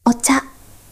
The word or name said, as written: おちゃ